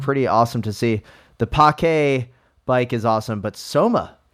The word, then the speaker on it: Pake: So is the new Albion Privateer, formerly known as the Pake Seamute. I think it's pronounced Pake. – Pake. – Pake. Pake. P-A-K-E, and I apologize.